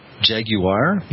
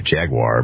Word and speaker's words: Jaguar: Jaguar? – Yeah, that's how the Brits say it. And we say Jaguar,